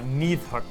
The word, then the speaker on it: Nidhogg: Well, it would seem that the leader's pretty much set in stone already, because today we are playing Nidhogg. – Yeah, or Nidhogg, as it would be pronounced properly in Icelandic.